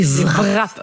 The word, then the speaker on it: wrap: Hein, les wraps. – Les wraps. Ça s'écrit wrap, mais ça se prononce wrap.